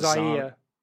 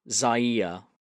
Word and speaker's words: Zaire: I think it's pronounced Zaire. – Zaire.